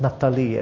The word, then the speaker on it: Naphtali: Then Naphtali, verse 23. And by the way, you can pronounce it as Naphtali as well. It's not wrong.